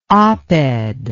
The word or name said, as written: oped